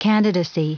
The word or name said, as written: candidacy